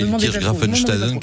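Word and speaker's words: Illkirch-Graffenstaden: Ilkirch Grafenstaden, je le dis, mais vraiment, alors là, mais facile.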